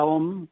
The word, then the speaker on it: om: Sí, la, la sílaba OM, que en sánscrito es una sola sílaba, en realidad es A-U-M. Y la A no es una A, la U no es una U y la M es una M con una componente de N.